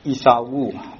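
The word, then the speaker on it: Esaú: 他们的牧师叫做伊萨乌，就是西班牙语的发音哈。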